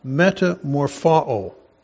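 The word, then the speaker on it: metamorphao (μεταμορφόω): The word he was transfigured before them, that word transfigured is a Greek word pronounced metamorphao.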